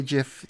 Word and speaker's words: GIF: But he says GIF, so I will honor his memory and say GIF, despite my distaste for that pronunciation.